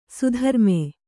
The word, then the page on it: ಸುಧರ್ಮೆ: sudharme